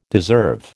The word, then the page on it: deserve: American English